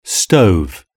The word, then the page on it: stove: British English